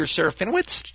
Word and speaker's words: Serafinowicz: And in this case, it's Peter – how do I pronounce his last name, Ali? Serafinowicz? Is that right? Peter Serafinowicz? – Sure.